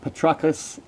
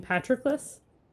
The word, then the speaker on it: Patroclus: him who Patroclus had foredoomed to slay in Troy's rich soil, far from his native land. – You mean Patroclus? – Yep, I totally said it wrong.